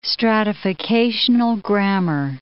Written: stratificational grammar

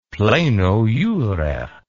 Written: pleno jure

